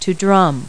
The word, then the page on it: drum: verb